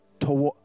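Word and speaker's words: ta'am: The first letter in the taste and see that the Lord is good is tam, or sorry, toam. I said that wrong. So it is a verb, phonetic spelling. Say toam.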